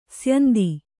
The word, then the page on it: ಸ್ಯಂದ: syanda